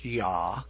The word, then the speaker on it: JA: It's talking about governments, and it specifically names a particular concern is German and Austrian agency of the Jugendamt, which is actually abbreviated JA, which would be pronounced YA, the officers.